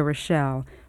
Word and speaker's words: Rochelle: Rita Rochelle. My name is spelled R-I-T-A, Rochelle is R-O-C-H-E-L-L-E.